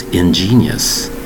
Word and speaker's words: ingenious: Today's word is ingenious. Ingenious. With three distinct syllables. It's spelled I-N-G-E-N-I-O-U-S. Ingenious. Ingenious. There is no J in this word. Just a big G. Spelled I-N-G-E-N-I-O-U-S.